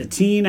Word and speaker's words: Etienne: Etienne at the Texans, and Nick Chubb's at the Commanders.